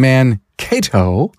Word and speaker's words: Mankato: And Greg Bednar from Greg's Champion Auto in Mankato.